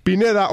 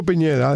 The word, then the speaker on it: Pineda: Pineda o Piñeda, depende cómo lo quieren pronunciar,